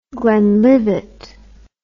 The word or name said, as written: glenlivet